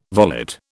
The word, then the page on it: volet: British English